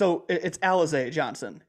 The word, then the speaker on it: Alize Johnson: It's Alize Johnson is how you pronounce it.